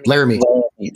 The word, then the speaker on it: Laramie: Laramie.